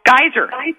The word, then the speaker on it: Geiser: It's Joe Geyser Hotel, right? – Pardon me? – Joe Geyser Hotel? – Geyser. It's spelled G-E-I-S-E-R. It's pronounced like the geysers in Yellowstone, but it's spelled G-E-I-S-E-R.